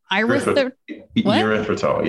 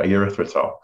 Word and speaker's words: erythritol: Erythritol. Yeah. Erythritol.